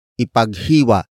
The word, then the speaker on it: ipaghiwa: Word for word it's ipaghiwa mo siya ng lechon.